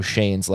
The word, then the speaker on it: Schoen: Only big because we learned how to pronounce Joe Shane's last name, S-C-H-O-E-N, pronounced Shane.